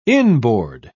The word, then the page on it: inboard: British English